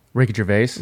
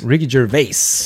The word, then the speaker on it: Ricky Gervais: So, Ricky Gervais, how do you say his name? – Ricky Gervais. – Ricky Gervais.